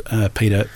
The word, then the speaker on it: Petre: The other one is called Peeder, PETRE. It's weird. – That's very weird. That's Petra. – Yeah, I know it is. Surely it's. But he pronounces it Peeder.